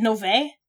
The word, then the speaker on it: Novae: Because it sounds like the two main characters in I've been saying it Novae, so is it Novae? – Yeah, that's how we pronounce it.